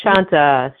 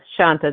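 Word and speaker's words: Sonta: Yes, Shanta. Shanta, there you go.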